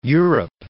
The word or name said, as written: Europe